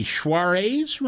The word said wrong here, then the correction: Saoirse: Ronan? – Saoirse Ronan. – That's how I pronounce it.